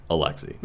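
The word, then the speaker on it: Aliaksei: Alexi. – Mm-hmm. – After you said it, I was like, oh, okay, yeah, that's clearly Alexi, but, like, what a weird way to spell it. – Because I'm not wearing my glasses, it looks like A-L-L and not A-L-I.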